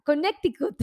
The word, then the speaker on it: Connecticut: Gladys de Perú, de Connecticut. Ah, ahora sí lo pronuncié bien, mi querida Gabriela. Connecticut.